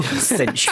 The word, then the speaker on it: Cinch: Cinch.